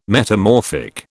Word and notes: metamorphic: British English

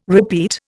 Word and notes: repeat: Indian

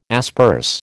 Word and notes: asperse: American English

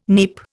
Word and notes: neep: Indian

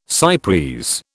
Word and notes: cy pres: British English

